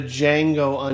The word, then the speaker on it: Django: It's actually called The Django Unchained. The D is silent.